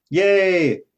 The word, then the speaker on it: Ye: Yay! Like that. – Legally. – That's how he pronounces it, right? – I Yay!